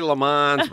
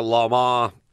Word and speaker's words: Le Mans: You say Le Mans, but it's kind of Le Mans is the way they say it.